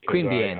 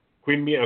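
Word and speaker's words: Queanbeyan: On September 5th, George Lazenby, Jor-El, and I want to put quotes around that, in the Superboy TV series, was born in, you're going to have to pronounce that for me, sir. – Queen Bien. – I don't. Queen Bien, okay.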